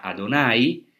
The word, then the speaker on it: Adonai: que hoy se pronuncia Adonai, porque no se puede pronunciar, no se pronuncia Yahvé como hacemos,